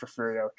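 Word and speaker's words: Refugio: I know there's a G in there, but it's pronounced Refurio. Trust me, I live in Texas.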